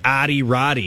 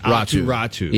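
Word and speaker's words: Aatu Räty: It really looks like Adi Rati. – Yeah. – But Atu, it's pronounced Atiratu.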